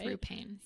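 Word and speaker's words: Roo Panes: Rue, R-O-O. – Okay. – P-A-N-E-S. – Okay. Yeah. – I'm pretty sure it's Rue Paines.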